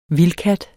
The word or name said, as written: vildkat